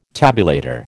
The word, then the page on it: tabulator: American English